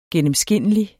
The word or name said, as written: gennemskinnelig